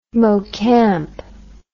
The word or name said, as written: mocamp